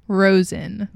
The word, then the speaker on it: Rosen: Rosen.